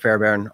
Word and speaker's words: Fairbairn: Um, or Instagram is just Robert Fairbairn, all one word, my name.